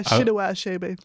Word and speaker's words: Chinua Achebe: Yeah, Chinua Achebe. Yeah, that actually almost sounds a little bit Irish. Chinua. Chine Chinua. – Everything sounds Irish. – It does. So, Things Fall Apart by Chinua Achebe has been translated to Irish as Tit and Ruddy Osachela.